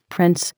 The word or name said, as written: prince